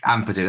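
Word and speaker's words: Ampadu: Obviously, Leicester have Ndidi, Drewsbury, Hall and Winks, but to have Ethan Apam, I think it's Amapadu, I think is how you say his name. I can't actually say his name. It's very weird. Amapadu, that's the one.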